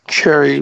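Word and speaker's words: Xerri: But you reckon Tristan, is it Zeri? Is that Cherry? I think Cherry was how they pronounced it. – Cherry would be what I'd go with, yeah.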